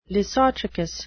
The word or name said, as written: lissotrichous